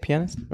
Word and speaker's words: pianist: pianist. Is that how you say it? Pianist, right? – Pianist. – Yeah.